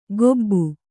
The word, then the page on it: ಕೊಬ್ಬು: kannada; kobbu